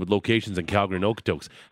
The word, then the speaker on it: Okotoks: Okotoks. – Spell it. – It is O-K-O-T-O-K-S.